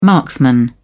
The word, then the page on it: marksman: British English